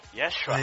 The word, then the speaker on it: Yeshua: É só ver no filme do Mel Gibson. Yeshua.